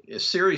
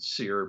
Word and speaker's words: SERE: And back in the Cold War, SERI spelled S-E-R-E, and nowadays I hear people pronounce it SEER, but we pronounce it SERI.